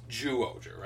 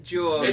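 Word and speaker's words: Zyuohger: Okay, by the way, it is pronounced Zuoger, right? – Zuoger. – It's spelled with a Z. – I know it is spelled with a Z, but... – It's Zu. – Yeah, but like even back, like the Sentai they made Mighty Morphin out of, that was called Zuoranger, right? – Yes. – It's spelled Z-Y-U-Ranger. – So I think it is Zuoger. – But it is Zu, right? – Yeah.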